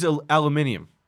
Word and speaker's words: aluminium: He's just pronouncing it differently. – Well, he's aluminium. – Aluminium. – He's being British.